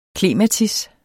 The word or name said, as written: klematis